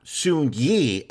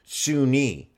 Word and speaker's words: Soon-Yi: This was the episode of Who is Soon-Yi, which is the correct pronunciation according to Mia Farrow.